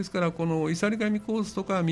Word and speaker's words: 岩座神: 岩座神と書いて、いさり神というもんです